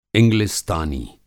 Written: इंग्लिस्तानी

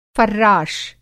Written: फ़र्राश

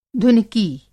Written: धुनकी

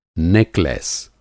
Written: नेकलेस

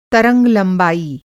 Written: तरंग-लंबाई

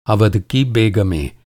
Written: अवध

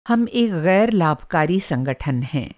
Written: ग़ैर-लाभकारी संगठन

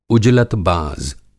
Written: उजलतबाज़

